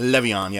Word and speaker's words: Le'Veon: Le'Veon, yes. – I'm saying it. – You can say it however you want, because I'm not going to say it the way I'm saying it. Le'Veon? – Le'Veon.